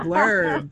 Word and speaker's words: blerd: B-L-E-R-D, blurred. – There you go, blurred.